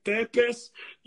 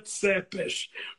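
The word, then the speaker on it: Țepeș: Escreve tepes, em romeno se pronuncia tsepes, ou o empalador.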